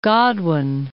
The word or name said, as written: Godwin